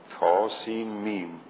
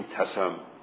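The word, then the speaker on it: طسم: اینجا هم تا سین میم، نمیگیم تسم مثلا که به مرکب بخونیم، اینجور تقطیع میکنیم، یعنی حروف را از هم جدا میکنیم. تا سین میم، این سه حرف از حروف در اول سوره شعراء آمده.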